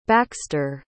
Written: baxter

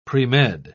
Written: premed

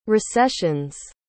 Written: recessions

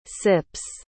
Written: sips

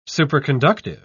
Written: superconductive

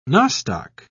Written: nostoc

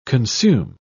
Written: consume

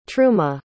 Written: truma

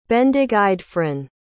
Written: bendigeidfran

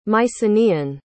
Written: mycenaean

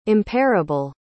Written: impairable

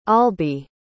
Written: albie

